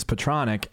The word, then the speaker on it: Petronic: My name is Petronic, and most people pronounce it Petronac because I think right. They see the P-E-T and they put it together as pet, and then they don't know what to do with the rest.